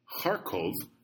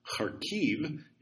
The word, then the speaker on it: Kharkiv: So, for example, Kharkov is the Russian pronunciation. Kharkiv is the Ukrainian pronunciation.